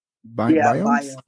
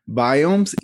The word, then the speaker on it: biomes: biomes. Is that how it's pronounced? – biomes. – biomes.